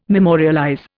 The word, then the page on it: memorialize: Indian